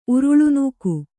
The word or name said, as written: ಉರುಳುನೂಕು